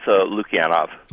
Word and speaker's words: Lukianoff: Is that pronounced Lukianoff? – It's Lukianoff.